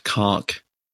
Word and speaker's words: conch: I think the line is literally Piggy has the conch, but it's actually pronounced conk.